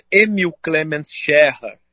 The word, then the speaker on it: Emil Clemens Scherer: Emil Clemens Scherer, essa é a pronúncia correta do nome dele, mas que foi aportuguesada aqui na nossa cidade como Emílio Clemente Scherer.